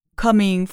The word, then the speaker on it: coming: Coming for a drive，一个迷人的欢快的声音说。好，那么这个地方的发音呢，有几个点。第一个是 coming，注意其中的 o 发的是短音啊，coming。